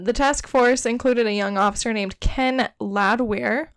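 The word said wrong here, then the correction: Landwehr: who... No. Ken Landweir.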